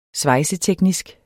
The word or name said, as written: svejseteknisk